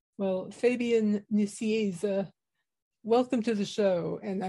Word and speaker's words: Nicieza: The way I say it is in Spanish, it's Fabian Nicieza. In English, it's Fabian Nicieza. And often in American, it's Fabian Nicunza.